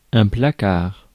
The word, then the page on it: jail: American English; [dʒeɪl]